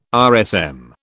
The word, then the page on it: RSM: British English